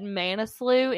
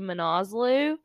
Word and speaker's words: Manaslu: I think we said Manaslu in part one, and it's actually Manaslu.